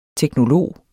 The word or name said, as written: teknolog